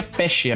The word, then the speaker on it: Pesce: A versão, porque Pess é um nome italiano, tá ligado, né? – Fraudou até o casamento, cara. – Que inclusive se pronuncia Pesce.